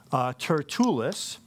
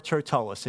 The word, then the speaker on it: Tertullus: And Felix invites up the leaders of the Jews, and they come with their spokesman, Tertullus. Or Tertullus. It can be pronounced either way.